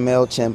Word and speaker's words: MailChimp: Support for cereal comes from MailChimp. – From MailChimp. – Mail came chimp. – That delightful young woman mispronounced us and called us MailChimp.